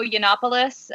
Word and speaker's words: Yiannopoulos: It was the college Republicans at Berkeley, and who knew that there were college Republicans at Berkeley, had invited Milo Yiannopoulos.